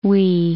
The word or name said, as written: Huy